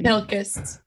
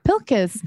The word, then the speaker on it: Pilkes: Pilkes. – Pilkes.